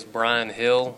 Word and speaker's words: Brian Hill: My full name is Brian Hill, B-R-I-A-N-H-I-L-L.